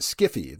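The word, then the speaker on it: sci-fi: There is sci fi, which author Harlan Ellison says should be pronounced skiffy.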